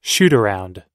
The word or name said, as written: shootaround